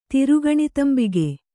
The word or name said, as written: ತಿರುಗಣಿತಂಬಿಗೆ